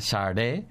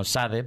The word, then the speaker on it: Sade: Una se llama Sade, aunque, aunque la gente la conoce como Sade porque, porque es como se escribe.